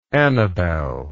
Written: annabel

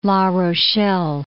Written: La Rochelle

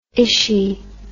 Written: Ishi

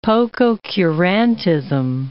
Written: pococurantism

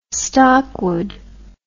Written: stockwood

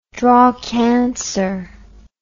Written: drawcansir

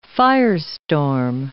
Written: firestorm

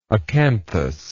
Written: acanthous

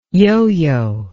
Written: yoyo

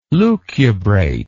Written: lucubrate

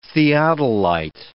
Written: theodolite

